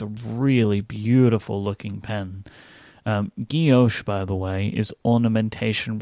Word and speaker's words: guilloche: Google has a little speaker button where it will pronounce it, and that was how it was pronounced. So that's how I've always said it, is Guilloche.